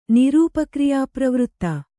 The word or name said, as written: ನಿರೂಪಕ್ರಿಯಾಪ್ರವೃತ್ತ